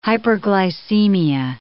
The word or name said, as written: hyperglycemia